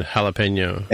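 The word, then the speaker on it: jalapeño: I guess, So the jalapeno, which is spelled J-A-L, right, is pronounced with the H sound at the beginning, ha. So the J is H, but the...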